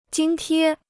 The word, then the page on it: 津贴: jīn tiē